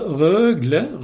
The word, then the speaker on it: Rögle: Alltså, Rögle. Nej, så du ska på tung betoning på första som Rögle.